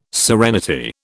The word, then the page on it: serenity: British English